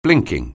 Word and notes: blinking: anglais

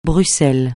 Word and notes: Bruxelles: francais

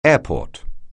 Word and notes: airport: English

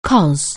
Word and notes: cos: anglais

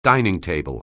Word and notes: dining table: anglais

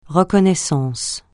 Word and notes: reconnaissance: francais

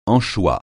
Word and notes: anchois: francais

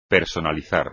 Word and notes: personalizar: espagnol

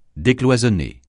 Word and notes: décloisonner: French

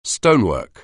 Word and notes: stonework: anglais

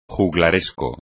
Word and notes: juglaresco: Spanish

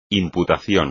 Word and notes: imputación: espagnol

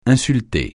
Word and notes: insulter: francais

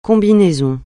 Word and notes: combinaison: francais

